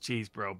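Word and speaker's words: Chesebro: That's attorneys Cheesebro and I can't believe it's pronounced Cheesebro, but apparently it is.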